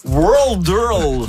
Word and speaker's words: Worldle: Audrey Anne, le jeu World Earl. – Oui, c'est dur à prononcer. W-O-R-L-D-L-E, comme le world, comme le monde.